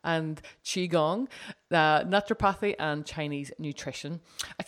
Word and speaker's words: Qigong: It's Tuina and Qigong. – Tuina and Qigong.